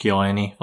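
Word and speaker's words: Kyoani: And plus, it's Kiyoi. Like, people are going to support Kiyoani. – It. Kiyoani. Fuck, I keep pronouncing it wrong.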